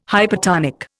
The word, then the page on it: hypertonic: Indian